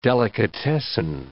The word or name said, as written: delicatessen